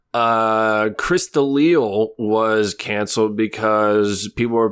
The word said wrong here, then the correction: D'Elia: Did I say his name wrong? – I have no idea who you're talking about. That's why I'm... – The comedian. – Oh, Chris D'Elia.